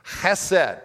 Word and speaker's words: Chesed: Chesed. Can you say that with me? Chesed. That wasn't very good. Clear your throat. Chesed. That's basically how you say it.